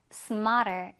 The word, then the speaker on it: smarter: 가운데 트 발음 R로 약화가 되면서 smarter. 이렇게 들렸어.